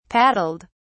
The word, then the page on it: paddled: British English